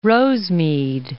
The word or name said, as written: Rosemead